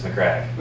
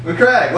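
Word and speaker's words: Macragge: Is that how it's pronounced? – Yes, McCrag. – McCrag. Well, now I know how to pronounce that darn word.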